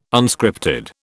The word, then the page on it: Unscripted: British English